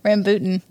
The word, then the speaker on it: rambutan: Had Rambuchis. – We had Rambuchis. – Is that how you say it? No. Rambutan.